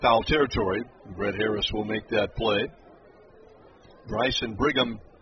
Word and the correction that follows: Brigman: Brigman, excuse me, Bryson, Bob, the guy's hitting 600. He's 6 for 10. Got to get his name right, Bryson Brigman on deck.